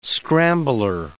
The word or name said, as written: scrambler